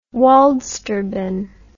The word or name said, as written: waldsterben